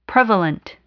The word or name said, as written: prevalent